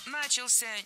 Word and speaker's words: начался: 14:00 начался огромный парад вдоль набережной. – Начался неверно. Правильно, начался. Ударение в этом слове падает на последний слог, поближе к возвратной части «ся».